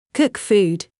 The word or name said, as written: Cook food